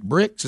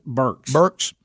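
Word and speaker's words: Birx: So on that note, so this thing that Dr. is it Bricks? Is that how you say her name? – Burks. – Yeah, that's right. – It's like B-U-R-K-S, but she spells it B-R-I-X.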